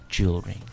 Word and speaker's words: jewelry: Again, we've got an extra vowel here that we don't need to pronounce. We don't say jewelry. – No. – Jewelry. – Jewelry. – It's just jewelry.